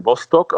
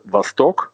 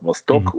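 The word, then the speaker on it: Vostok (Восток): Nee, also die Leute sagen Vostok, also einfach so. Richtig wäre Vostok, aber das sagen nur die wenigsten, beziehungsweise die wissen, dass das russische Wort tatsächlich die Betonung auf der zweiten Silbe hat, Vostok, und das bedeutet der Osten auf Russisch.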